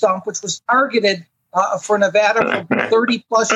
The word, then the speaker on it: Nevada: Nevada, Nevada, Nevada.